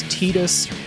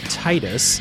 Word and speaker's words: Tidus: So the game opens in the high-tech city of Xanarkand, where the player protagonist, canon name is Tidus. I always pronounced it Titus because of the water theming of the game.